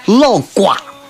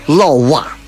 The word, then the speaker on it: 老鸹: 老蛙这两个字，本来在咱这儿应该念的是叫老瓜，对不对？老瓜，最后在这儿读成一声老蛙，意思啥呢？